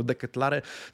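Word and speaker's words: De Ketelaere: W Milanie tematem numer jeden, e, przynajmniej z perspektywy Corriere dello Sport, jest oczywiście Charles de Kettler. Tak powinno się podobno wymawiać jego nazwiska, przynajmniej bazując na wywiadach w oryginale mm, przeprowadzanych z tym zawodnikiem, więc w porządku, niech będzie deketlar, niektórzy mówią deketlare, zobaczymy jakie, jaka forma przyjmie się w polskich mediach, w każdym razie pozwólcie, że będę mówił o nim deketlar.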